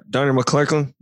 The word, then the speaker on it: Donnie McClurkin: Donnie McClurkin.